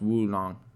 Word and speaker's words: Oolong: It's pronounced Wulong. – Is it Oolong? – Yeah, Wulong. – Why is it pronounced with a W? – It's Wulong.